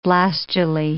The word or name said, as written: blastulae